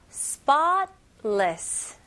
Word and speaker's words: spotless: 여기서 spotless, spotless. 그래서 잘 들어보시면 중간에 있는 T 소리가 그렇게 강하지 않아요. 그래서 T 소리가 나지 않고 혀가 천장을 닿긴 닿는데 바로 T 소리 나기 전에 L로 넘어가는 거예요.